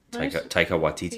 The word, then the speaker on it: Taika Waititi: The last Thor movie in particular, you can tell that Taika Waititi, I'm saying that wrong, Taika Waititi. – Yeah, you're saying it right.